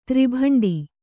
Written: ತ್ರಿಭಂಡಿ

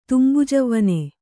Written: ತುಂಬುಜವ್ವನೆ

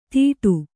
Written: ದೀಡು

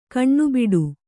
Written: ಕಣ್ಣುಬೊಟ್ಟು

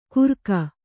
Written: ಕುರಕ

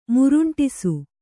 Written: ಮುರುಂಟಿಸು

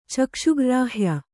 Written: ಚಕ್ಷುಗ್ರ್ರಾಹ್ಯ

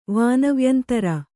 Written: ವಾನವ್ಯಂತರ

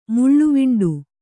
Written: ಮುಳ್ಳುವಿಂಡು

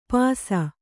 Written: ಪಾಸ

